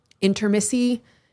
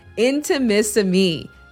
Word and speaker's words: Intimissimi: But the name can be a little challenging to pronounce. Intermissi, no, it is Intimissimi, the art of Italian lingerie.